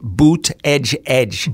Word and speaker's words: Buttigieg: Excuse me, Donald Trump pronounces it boot edge edge.